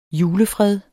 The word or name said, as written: julefred